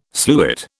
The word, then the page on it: sluit: British English